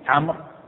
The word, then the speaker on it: عمرو: لما روى عمرو بن شعيب عن أبيه عن جده عن شوف اسمع عمرو شوف عمرو تكتب الواو ولا تنطق. عمرو كأمر كتابتها واحدة لكن ميزوا عمرو بزيارة الواو لأن في القديم كان لو كتبت كيف تتميز عمر وعمر إلا بالنطق. فلما جاءت الكتابة احتاج إلى التمييز حتى ليقرأ يفهم من هذا عمرو ولا عمر فأزاد الحرف الواو حتى تعرف أنها عمر وليست عمر ولا النطق ولا هي الأصل ما تزعد عمر وعمر واحد بس هي للتمييز. ولهذا لا تنطق لا تنطق عرفت.